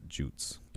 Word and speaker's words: Jutes: along with the Jutes, or some people pronounce it Jutes, but it's Jutes. It's a soft J. – How's Jutes? – J-U-E-T-S? – J-U-T-E-S. – J-U-T-E-S.